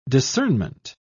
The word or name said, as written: discernment